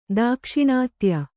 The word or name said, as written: ದಾಕ್ಷಿಣಾತ್ಯ